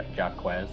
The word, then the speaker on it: Jacques: Honestly, I don't know how to pronounce the first one. Jacques. – Jacques. – Jacques. Oh, yeah. I'm so stupid. I knew that.